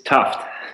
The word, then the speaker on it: Tuft: It's Tuft. – Tuft, sorry. But I like Tooft. It sounds tough. T-U-F-T.